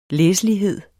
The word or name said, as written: læselighed